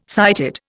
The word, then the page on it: sighted: Indian